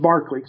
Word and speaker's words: Berkeley: Now, in England, it would be pronounced Barkley.